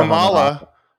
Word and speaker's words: Kamala: It's Kamala. The vice president has ruined my ability to say her name. It's you said Kamala, it's Kamala.